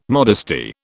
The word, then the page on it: modesty: British English